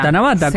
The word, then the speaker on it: Tanabata: Ah, sí, que el encuentro el día de Tana, no sé cómo se pronuncia bien, si alguno lo puede hacer, es el encuentro Tanabata, sí. – Tanabata. – Tanabata. – Tanabata, sí.